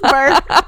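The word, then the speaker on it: beurre: It's just burr.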